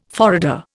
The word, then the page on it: forrader: Indian